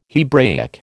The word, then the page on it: Hebraic: American English